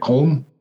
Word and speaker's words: Köln: I was at the Anga show, however you pronounce it, in Cologne, or in pronouncing Deutsch, what is it, Köln, Germany.